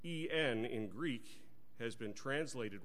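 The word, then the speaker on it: EN (ἐν): In the original language, that word by, God has spoken by his Son, it has two letters, the Greek E and the Greek N. N. It sounds like the word in, doesn't it?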